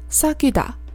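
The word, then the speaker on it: sagida (사귀다): 韩语里面对应的这个词呢是 “sagida”，要注意一下这第二个字的发音。这个字呢算是有一个复合的元音，是由元音 “u” 和 “i” 组成的。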